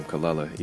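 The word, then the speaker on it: Kelela: But yeah, you mentioned she calls herself Kalula, but that's actually her name, Kalula, even though it's spelled Kalayla.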